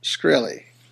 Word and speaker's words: Shkreli: The move by how you say it? – Shkreli. – Sounds like a krill.